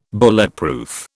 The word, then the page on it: Bulletproof: British English